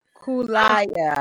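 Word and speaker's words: Kaluuya: How do you pronounce Daniel's last name? I always mess it up. – Kulaya, I believe.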